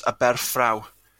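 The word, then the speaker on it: Aberffraw: I've also heard it pronounced as Aberfraw, just in case there's people out there. I call it Aberfraw. I've heard Aberfraw.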